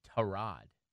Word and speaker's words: Tyrod: The other quarterback who used to play for Virginia Tech and the Ravens, the first name is spelled T-Y-R-O-D. How do we say that? – Tyrod. – Tyrod Taylor, right?